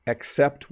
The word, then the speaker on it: except: But if you look at this picture, all of the circles are orange except one. So notice I pronounce that with a softened E sound, a little bit of an E instead of an A.